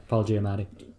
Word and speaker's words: Paul Giamatti: Yeah, Paul Giamatti.